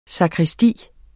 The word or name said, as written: sakristi